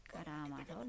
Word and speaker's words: Karamazov: La gracia que te he traído los hermanos Karamazov. Karamazov, lo he dicho bien, ¿no? Sí.